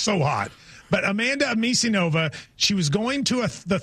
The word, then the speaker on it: Anisimova: Anisimova. – How do you say Anisimova.